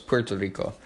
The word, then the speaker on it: Puerto Rico: says, quickly, while we're fighting this war, let's go get Puerto Rico, which is right next to us, Puerto Rico, if you want to say it correctly.